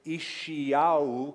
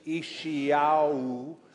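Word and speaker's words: Ishiyahu (ישעיהו): Ishiyahu is how you say his name. He wasn't born in Rochester, New York, or in California or New Mexico. He was born in Israel, and so his original pronunciation was Ishiyahu.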